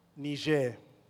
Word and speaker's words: Niger: They had prophets and teachers, and one of them was Simeon, maybe Simon, called Niger. That's the politest pronunciation I can come up with for his name, which in Greek means black.